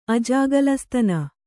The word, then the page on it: ಅಜಗಲಸ್ತನ: ajagaḷastana